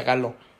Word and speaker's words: Galo: Y Galo, como gallo, pero se pronuncia Galo.